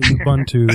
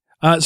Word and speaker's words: Ubuntu: It's not Ubuntu. It's Ubuntu. So you said Linux compatibility.